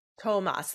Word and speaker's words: Tomas: How do we say his name? – Tomas. – That's what I thought.